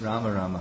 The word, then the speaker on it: Rama: Does Prabhupada say Ram, Ram, Hare Hare, or Rama, Rama? And it's Rama, Rama, Hare Hare. It's just he says it quickly.